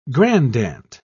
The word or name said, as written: grandaunt